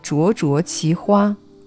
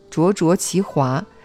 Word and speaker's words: 灼灼其华: 注意，这里要念灼灼其花，而不要念成灼灼其华。这个华是同花朵的花的意思。